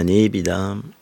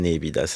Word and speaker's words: nepita: Mais nous, on dira que c'est la nébida, ça ne ressemble à rien d'autre. – Ça s'écrit N-E-P-I-T-A. – C'est ça.